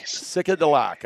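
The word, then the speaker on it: Sitkalidak: It's like psychedelic, but it's Sikadilak. – Sikadilak. Sikadilak, I think.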